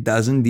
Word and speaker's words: DAZN: Is that how you say it? Dazen, D A Z N, Dazen, right?